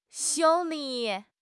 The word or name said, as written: xiu1 li3